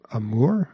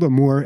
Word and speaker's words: Amour: Not Amore. It's not Italian. It's Dutch, but it's the movie's actually in French. It's by Michel Haneke, pretty famous director over there. But it's called Amour, and it's just about this old couple, and one of the ladies slowly dies in it.